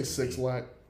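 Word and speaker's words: 6lack: Nigga, did you just say Six Lack? – That's his name. – That is his name. That's how you say it.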